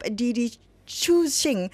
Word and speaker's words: Didi Chuxing: And what I mean by that is Apple is investing $1 billion into the car hailing app Didi Chuxing, which means little orange in Chinese.